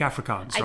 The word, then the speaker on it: Afrikaans: Okay. Afrikaans. You said that wrong. – Yeah. Sorry? – You said it wrong. Afrikaans. – That's not what I said. – Afrikaans. – Afrikaans. – Not Afrikaans. Not in America.